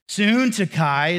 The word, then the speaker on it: Syntyche: Syntyche is how you really pronounce the other name.